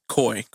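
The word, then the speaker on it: Coi: Me, I feel like she's like a Corey LeRae, like Corey LeRae. – Or what? How you pronounce her name? – Coy.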